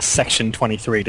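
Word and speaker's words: Section23: It's pronounced Section 23, despite the odd spelling.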